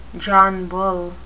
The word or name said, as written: John Bull